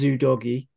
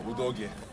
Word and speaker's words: Udogie: Can I just say, I have checked how you pronounce Destiny's name back from his interview. It is Udogie.